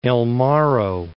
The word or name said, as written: El Morro National Monument